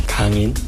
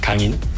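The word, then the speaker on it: Kang-in: ¿Cómo se pronuncia tu nombre correctamente? – Kangin. – ¿Cómo prefieres que te llamemos? – Kangin.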